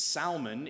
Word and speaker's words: Salmon: That is how we pronounce it in English, but it's Salmon in Hebrew.